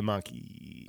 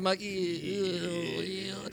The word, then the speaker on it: Mach-E: Well, and it turned out that everybody was mispronouncing the name of the car. It was the Mach-E. – The Mach-E.